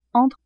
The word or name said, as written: entre